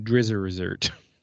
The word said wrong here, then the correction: Drizzt: Drizzt. Yeah, Drizzt.